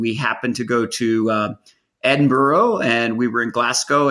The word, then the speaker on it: Edinburgh: First off, I'm going to have to teach you how to say the word, you know, the city Edinburgh. It's Edinburgh. Come on, Thom. – Edinburgh.